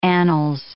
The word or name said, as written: annals